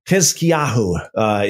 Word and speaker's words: Hizkiyahu: Just a little footnote here in the Hebrew, Hizkiyahu. Hizkiyahu is how you pronounce Hezekiah in the Hebrew.